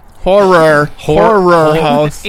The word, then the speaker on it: Horror House: You mentioned your film Whorehouse. – Horror House. – H-O-R-R. – Several syllables.